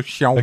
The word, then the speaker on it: Xiaomi: Nee, nee, wirklich, also das wird wie SCH ausgesprochen, ne? So Xiaomi.